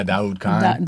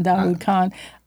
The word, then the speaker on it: Daoud Khan: Yeah, Daoud Khan. – Daoud Khan.